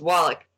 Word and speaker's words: Walloch: Sure, it's Walloch.